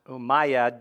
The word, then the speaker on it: Umayyad: In 38 AD, the city of Jerusalem is controlled by Muslim, I think it's pronounced Umayyad dynasty.